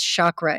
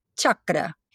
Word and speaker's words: chakra: I don't know why, but they think that the word is chakra. And what I tell them is, if it shocks you, it's not a chakra. The word is a ch sound and it's pronounced chakra.